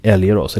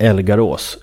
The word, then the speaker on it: Älgarås: en gammal fabrik eller något sånt där i Älgarås, eller Älgarås, lite osäker på hur man uttalar det, i Töreboda.